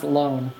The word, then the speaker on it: Lohan: It's pronounced Lone.